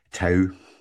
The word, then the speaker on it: tau: So, firstly, you mentioned there about the tau, if I pronounced it correctly.